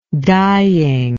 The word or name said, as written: dyeing